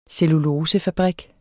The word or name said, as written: cellulosefabrik